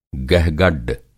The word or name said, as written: गहगड्ड